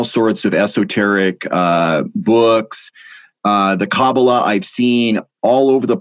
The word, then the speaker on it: Kabbalah: Am I pronouncing that right? Kabbalah. – Yes, that's right.